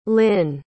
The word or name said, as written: lynne